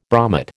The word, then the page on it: bromate: American English